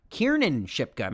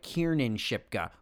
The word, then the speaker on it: Kiernan Shipka: Right. Shipka? Yeah, Shipka. Kiernan Shipka. Make sure I get it right. Kiernan Shipka.